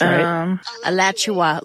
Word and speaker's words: Alachua: Alachua? Is that how you say it? – Alachua, like Joshua, but Alachua.